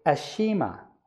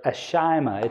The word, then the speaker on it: Ashima: And then it says, and the men of Hamath made Ashima, or Ashima. It depends on, the pronunciation can change.